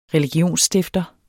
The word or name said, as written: religionsstifter